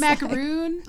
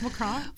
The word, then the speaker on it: Macron: Macron? That's what it looks Macaroon. – Like. Macaroon. – I'm sorry. No, I know it's Macron, but. – Macron, yeah.